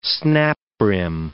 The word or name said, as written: snap-brim